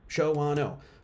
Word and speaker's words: Shawano: How many syllables is Shawano? It's a Native American term, Shawano. Shawano. Two syllables, Shawano. Shawano.